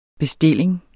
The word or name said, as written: bestilling